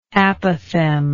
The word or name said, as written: apophthegmatical